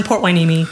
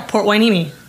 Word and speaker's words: Port Hueneme: We're in Port Hueneme, California. – That's not how it's spelled, but that's how you say it. There's a naval base like right over there. – Yeah, Port Hueneme.